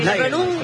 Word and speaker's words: Nagelsmann: Claro, es Nagelsmann.